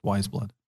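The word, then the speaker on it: Weyes Blood: It's spelled W-E-Y-E-S. Blood. – W-Y-E? – W-E-Y-E-S. – Okay. Yeah. – Like way-yes almost, but it's pronounced Wise Blood.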